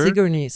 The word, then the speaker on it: Sigourney: Sigourney.